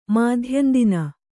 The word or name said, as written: ಮಾಧ್ಯಂದಿನ